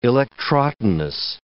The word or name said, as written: electrotonus